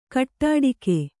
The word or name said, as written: ಗೋಟಡಕೆ